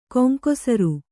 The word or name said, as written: ಗಂಗಸಾರ